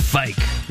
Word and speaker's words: Fike: It's Fike. You know, rhymes with bike.